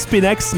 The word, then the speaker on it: Spinx: C'est le Spinx. S-P-I-N-X. Je sais pas si on le prononce Spinax, mais moi, je prononce Spinx.